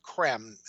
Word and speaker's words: Creme: His name is spelled C-R-E-M-E, okay. Benjamin Krem. It looks like cream, but it's not.